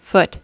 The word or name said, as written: foot